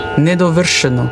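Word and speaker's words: nedovršeno: Also nicht sch, sondern sch. Nedovrscheno.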